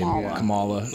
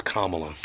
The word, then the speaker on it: Kamala: Kamala. – No, it's Kamala.